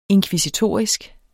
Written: inkvisitorisk

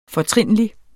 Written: FORTRINLIGE

